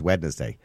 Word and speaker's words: Wednesday: Yeah, well, it is actually, its proper pronunciation is Wednesday. – Wednesday. – Yeah, you're actually kind of meant to kind of sneak the N in, but nobody ever does.